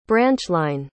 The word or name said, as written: branchline